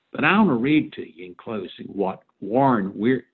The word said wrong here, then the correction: Wiersbe: let me say it right, Warren Wiersbe wrote about Abraham's visit in Gerar here in chapter 20.